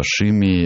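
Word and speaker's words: Simidh: А Шимми почему? Потому что Саймон – это имя вот, в английской огласовке. Это традиционное имя для фрейзеров. А Шимми – это вот гельское произношение.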